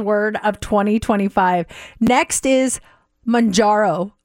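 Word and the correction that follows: Mounjaro: They have it phonetically here and it says Monjaro. – Okay. – Monjaro.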